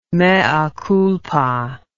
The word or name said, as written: mea culpa